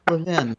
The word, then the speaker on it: Levin: It's any way you want to pronounce it, and I like Levin.